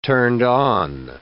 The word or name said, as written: turned-on